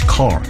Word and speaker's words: CAR: So what medical scientists do is to put a genetic GPS tracker, which is medically called as chimeric antigen receptor, in short form CAR, or we pronounce it as CAR, to the specific type of immune cells, which is T cells.